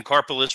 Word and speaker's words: Karpeles: Karpolis, carpal tunnel syndrome. That's what I remember it now as. It's a good way to remember it.